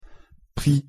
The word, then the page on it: prix: French